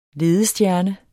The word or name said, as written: LEDESTJERNER